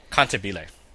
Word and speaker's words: Cantabile: Cantabile. – It's music. Cantabile.